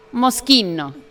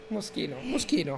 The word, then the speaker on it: Moschino: Yo les dije, para empezar, esta marca es italiana y no les puedo decir con certeza, pero creo. Que es Moschino. – Moschino. – ¡Y lo dije bien!